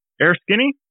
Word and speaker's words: Erskine: Erskine Radio? – Erskine. – E-R-S-K-I-N-E? Erskine?